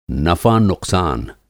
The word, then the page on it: नफ़ानुक़सान: नफ़ा-नुक़सान